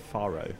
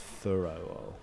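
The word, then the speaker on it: Fårö: and they've gone to Faro, which is the sort of anglicized pronunciation of the island where Ingmar Bergman lived for most of his later life, in sort of rural seclusion. I think it's pronounced Furo.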